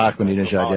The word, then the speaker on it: Ahmadinejad: Ahmadinejad, yeah, that's okay.